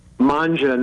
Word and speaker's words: Mangia: Manja Nashville, M-A-N-G-I-A, Manja.